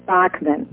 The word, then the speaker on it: Bachmann: Well, Dr. Stan back here at Radio Liberty, and our guest this afternoon is Senator Michelle Bachmann, and she's, am I pronouncing that last name right? – It's Bachmann, like the composer.